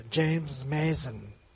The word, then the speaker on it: James Mason: senti la pronuncia, James Mason come Perry Mason.